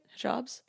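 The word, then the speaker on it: hijab: Hijabs? – Hijabs.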